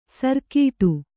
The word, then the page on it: ಸಾರಗೆಡು: sārageḍu